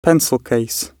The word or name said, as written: pencil case